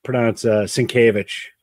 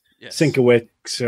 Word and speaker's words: Sienkiewicz: I mean, he was so back in the kind of the pre-internet days, you know, as we kind of joked, nobody knew how to pronounce Sienkiewicz. It was always Sienkiewicz, or you would literally pronounce every consonant was pronounced.